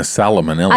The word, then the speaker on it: salmonella: Wait, I always say that wrong. – It's not a salmonella. – I always say salmonella. We've done this on the podcast before. How do I say salmonella? – Sure. Salmonella. – It's salmonella. – Yep. Sounds better.